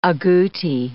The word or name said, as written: agouti